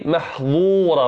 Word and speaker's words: محظورة: وتوجد كلمة أخرى في كتاب الله محظورة بالضاء محظورة.